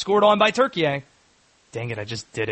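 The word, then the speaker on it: Türkiye: It's, yeah, that is how it's spelled. But they now say the pronunciation is Turkey.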